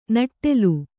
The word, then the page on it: ನೆಟ್ಟೆಲು: neṭṭelu